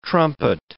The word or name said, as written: trumpet